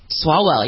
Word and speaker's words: Swalwell: Is it Swalwell? Is that how you pronounce his name? – Swalwell, yep, he's out.